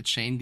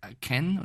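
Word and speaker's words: Achane: Er hat selber gesagt, er möchte nicht Chain genannt werden, sondern Ken oder Chen, ich weiß, Devon.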